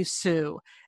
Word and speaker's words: Tsui: It's called Why We Swim by Bonnie Sue. And Sue is spelled T-S-U-I.